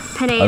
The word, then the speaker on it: Pineda: Daniela Pineda, 30 years old, 29 years old, lives in Brookland. – We're on the set of the originals with Daniela. Is it Pineda? – Pineda.